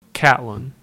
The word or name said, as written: Catlin